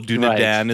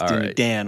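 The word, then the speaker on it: Dúnadan: Dunedain is the plural. Dunedan right. is the singular. All right. – That's what it was. Dunedan right.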